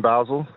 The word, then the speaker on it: Basel: Yeah, in Basel.